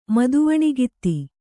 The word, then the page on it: ಮದುವಣಿಗಿತ್ತಿ: Kannada